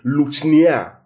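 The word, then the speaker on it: Luchnia (λυχνία): Now, the candlestick is the Greek word luchnia. I'll spell it for us. L-U-C-H-N-I-A. Luchnia. Luchnia.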